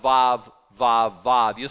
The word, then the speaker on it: Vav: It sounds like V, it's pronounced Vav. It's like V as in vine, it's number six.